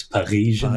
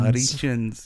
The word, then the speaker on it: Parisians: One of my favorite things in any number is how she doesn't really pronounce the R in Parisians. It's Parisians. – Parisians, yeah.